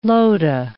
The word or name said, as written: lotah